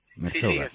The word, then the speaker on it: Merzouga: Por ejemplo, venía el pasado día 4 en el diario El Mundo y decía, etapa 3, Merzouga, no sé si se pronuncia así. – Merzouga. Sí, dice, miércoles día 31, el rally solidario se adentra en las tribus más pobres donde casi nunca llegan ni los turistas.